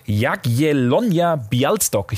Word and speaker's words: Jagiellonia Białystok: Und den zieht es nach unseren Infos zum polnischen Meister Jagiellonia Białystok. Ich hoffe, ich habe es richtig ausgesprochen. Polnischer Meister spielt auch international. – Hätte keiner besser sagen können als du.